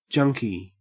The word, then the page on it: junkie: Australian English